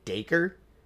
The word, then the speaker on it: Dacre: It's a guy by the name of Dacre. – Yeah, I think that's how it was pronounced. – That's how yeah. – it's pronounced.